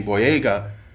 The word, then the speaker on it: Boyega: Boyega. If I said that right, I said it right the first time.